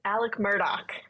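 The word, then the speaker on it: Alex Murdaugh: Alec Murdoch.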